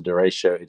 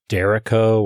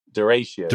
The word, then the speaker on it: derecho: I think I've always pronounced it like Derrico, or like rhyming with Jericho. So pronounce that one for me again. – Derecho.